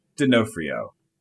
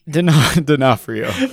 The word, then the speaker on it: D'Onofrio: Am I saying his name right? I feel like you would know. – Absolutely not. – I'm not? – D'Onofrio. – D'Onofrio? – But it's fine. – I was pretty close.